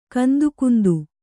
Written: ಕಂದುಕುಂದು

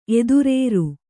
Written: ಎದುರೇರು

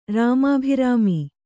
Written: ರಾಮಾಭಿರಾಮಿ

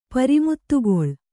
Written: ಪರಿಮುತ್ತುಗೊಳ್

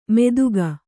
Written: ಮೆದುಗ

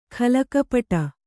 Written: ಖಲಕಪಟ

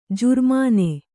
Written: ಜುರ್ಮಾನೆ

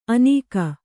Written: ಅನೀಕ

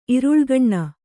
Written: ಇರುಳುಗಣ್ಣ

